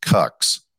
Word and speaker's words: QUX: In the mental joke universe, The device is spelled QUX, but it's pronounced cux.